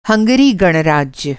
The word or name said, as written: हंगरी